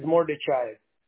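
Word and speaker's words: Mordechaev: Last name is Mordechai.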